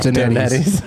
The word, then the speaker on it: Donettes: It's Donettes, but it's a running gag on the show that... – First timer. – Emma Arnold, on this very stage, admitted that her whole life she thought they were pronounced Donettes.